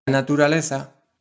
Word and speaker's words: naturaleza: The Z is pronounced like th in the English word think. So instead of naturaleza, you'll hear naturaleza.